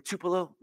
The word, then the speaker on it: Tupelo: I don't know, how do you say that name? T-U-P-E-L-O. Tupelo, thank you.